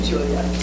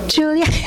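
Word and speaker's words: Giulia: G-I-U. – Oh, no, G-I would be a soft G. It's like chi. C-I is a chef. It's a soft, soft dessert. Just say Julia. – Julia.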